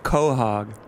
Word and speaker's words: quahog: I don't think they say the quo. It's just more like quahog.